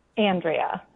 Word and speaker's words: Andrea: Andrea. It's a proper Michigan pronunciation, Paul W.